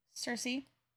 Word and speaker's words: Sursea: Also, how did you say her name? S-U-R-S-E-A. – Cersei. – Okay, that's what I did, too. It reminds me of, like, literally Greek mythology. – Sir, S-U-R-C-S-E-A.